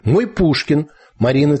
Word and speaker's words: Мой Пушкин: Например, «Мой Пушкин» Марины Цветаевой с ударением на «мой».